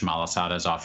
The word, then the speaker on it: malasadas: How's it spelled? – M-A-L-A-S-A-D-A-S. – Malasadas. – Yeah.